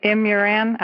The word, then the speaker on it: Imuran: Imuran, I-M-U-R-A-N.